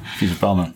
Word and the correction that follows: Vipassana: Ja, Vipassana. – Vipassana.